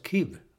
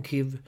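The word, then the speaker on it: Kyiv: Sonny Karlsson, han hävdar att Kiev, staden, han hävdar att den uttalas Kiv nu. Och jag har nog hört det också. Man hör rapporter nu direkt från Kiv.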